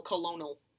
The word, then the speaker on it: Colonel: Colonel. Why is it? But then pronounced Colonel. Where where's the R? – Someone...